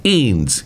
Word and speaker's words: Eanes: Number four, I think it's pronounced Eanes, E-A-N-E-S.